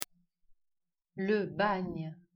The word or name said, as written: Bagne